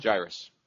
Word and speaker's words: Jairus: Jairus.